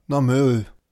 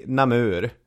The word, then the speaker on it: Namur: En kvinna från det flanderska grevskapet Namur, eller Namur. Vad känner du där? – Ja, Namur. – Då säger vi Namur.